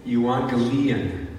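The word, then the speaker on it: Evangelion (Εὐαγγέλιον): The word on the left, there's two gammas. It's that little Y-shaped thing. It's a G sound, but when you've got them together, you pronounce it with an N sound. Evangelion is how you pronounce it.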